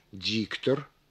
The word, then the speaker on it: диктор: Ну, например, произнося слово «диктор», мы выделяем первый слог некоторым усилением, замедлением и понижением голоса.